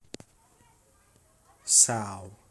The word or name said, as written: sough